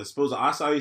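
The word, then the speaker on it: açaí: Acai. It's spelled A-C-A-I. You ever see nothing spelled A-C-A-I? – Nah.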